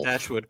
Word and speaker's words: Dashwood: Yeah, I said that right, right? Yeah. Mm-hmm. Dashwood, correct.